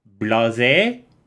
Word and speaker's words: Blaise: One is named Pascal, named after, I don't know how to pronounce his first name because it's French, but Blaise, I guess? B-L-A-I-S-E, Pascal.